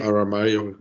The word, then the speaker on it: Aramayo: Aber apropos Elfen, ich muss auch sagen, dass unser Elrond, also Robert Aramayo, hoffentlich richtig ausgesprochen, Aramayo, ja.